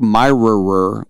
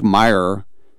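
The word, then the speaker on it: Mirer: And Rick Meyer, with the silent R in his name, Rick Meyer, he was Rookie of the Year with Seattle and then never did anything again.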